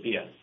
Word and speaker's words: Bia: And Tom, is Bia the name of the company, B-I-A, pronounced Bia, is it short for something or what does it mean? – It's the Irish word for food.